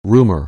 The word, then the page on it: rumor: anglais